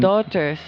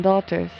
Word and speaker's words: Daughters: y los vamos a dejar con las Doctors, o como, es que no sé cómo se dicen. – Daughters, es en Daughters. – Inglés. Daughters.